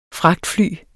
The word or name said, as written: fragtfly